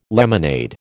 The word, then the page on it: lemonade: American English